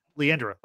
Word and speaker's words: Leandra: but his soul goes to space with Lalandra, Leandra, how do you say it? – Yeah. – Leandra. Like, Leandra. Right? Yeah, okay.